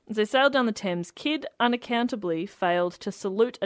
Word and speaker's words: Thames: It says, "As the Adventure Galley sailed down the Thames..."